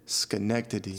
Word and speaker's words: Schenectady: Schenectady? – Schenectady.